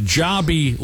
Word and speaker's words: Jobi: It's called Jobby Liquors, J-O-B-I. It's pronounced Jobby Liquors.